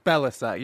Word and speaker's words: Balasai: Balasai, yeah. – Balasai. – Yeah. – Because it's spelled Balasai. – Yeah. – Am I right? – It is spelled how it sounds.